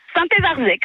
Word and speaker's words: Saint-Évarzec: Comment ça se prononce, votre commune ? – Saint-Évarzec, vous l'avez bien prononcé.